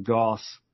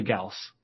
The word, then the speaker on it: Gauss: And I was like, no, we're going to pronounce it Goss, uh, just because I've always mis- mispronounced it my whole life from sci-fi punk gaming, Goss cannon, Goss rifle. Um, but it's actually Gauss, uh, is how you would pronounce, you know, Gaussian distribution, things like that.